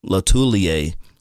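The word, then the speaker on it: Lotulelei: Star Latulile, that's how he said he pronounced it.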